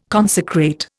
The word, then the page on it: consecrate: Indian